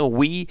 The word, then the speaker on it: Wii: Wii heißt ja auf Englisch wir, also zusammenspielen könnte man daraus interpretieren. Zwar wird das französische Wii ganz anders geschrieben, aber Wii und Wii klingt jeweils gleich ausgesprochen.